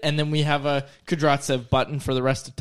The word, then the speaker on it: Kudryavtsev: But the way to pronounce that name, let me see it again. – Kudryatsev. – You don't pronounce the Y. So it's Kudryatsev.